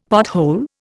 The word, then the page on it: pothole: Indian